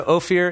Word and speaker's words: Ophir: O-P-H-I-R? – Ophir.